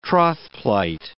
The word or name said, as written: trothplight